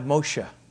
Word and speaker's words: Moshe: Now, in the Hebrew language, the Egyptian pronunciation escapes them. They can't say it the way the Egyptians would have said it. They say it more along the line of Moshe.